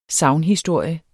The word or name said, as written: sagnhistorie